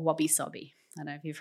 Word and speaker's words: wabi-sabi: No, wasabi. Wabi-sabi. So it's actually spelt okay. W-A-B-I. Actually, it's spelled in English. Yeah. Obviously, it's spelled in sort of English letters. Okay. W-A-B-I-S-A-B-I. But okay. It said wabi-sabi.